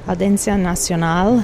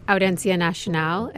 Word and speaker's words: Audiencia Nacional: Okay, so you've been to the court um, this is the Audiencia Nacional. Am I saying that right? Almost. You're, you're getting better. – Audiencia Nacional.